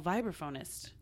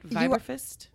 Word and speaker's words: vibraphonist: I did not know that you're a professional vibraphonist. Saying that right? Vibraphist? – Vibraphonist, that's right.